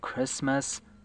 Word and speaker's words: Christmas: wonder why Christmas missed us. من الان کریسمس خوندمش، ولی خب کاملا واضحه که میشنویم کریسمس رو بیگی کریسماس تلفظ کرده تا با دست آس و مست آس قافیه بشن.